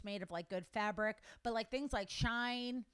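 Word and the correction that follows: Shein: Is that how you say it? – Sheen. Sheen.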